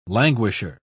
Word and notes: languisher: British English

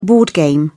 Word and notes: board game: British English

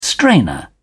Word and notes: strainer: British English

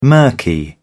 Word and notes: murky: British English

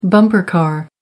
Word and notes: bumper car: American English

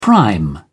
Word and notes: prime: British English

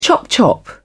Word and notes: chop chop: British English

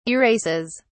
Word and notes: erases: British English

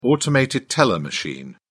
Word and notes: automated teller machine: British English

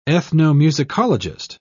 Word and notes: ethnomusicologist: British English

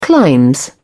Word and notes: clime: British English

